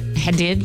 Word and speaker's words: Hadid: And the other one is, how do you pronounce her name? Gigi? – Hadid. – Hadid.